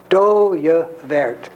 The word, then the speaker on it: Dooyeweerd: But the double O here is not do, as so it's do. It's a long O. Dooyeweerd.